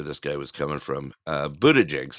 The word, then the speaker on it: Buttigieg: Buttigieg? Or is that like, I can't remember. – Buttigieg. – Buttigieg.